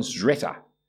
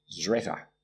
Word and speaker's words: Szreter: But by two academics in England, Simon Zretter. I'll spell it for you because you won't believe it. S-Z-R-E-T-E-R. Zretter. Simon Zretter.